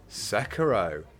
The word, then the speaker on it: Sekiro: First up, from Rags to Riches, Sekiro, correct pronunciation, won this year's Game Awards.